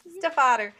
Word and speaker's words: Stiefvater: And that is The Scorpio Races by Maggie Stiefvater. Is that how we say her name? – Stiefvater.